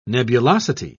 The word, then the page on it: nebulosity: British English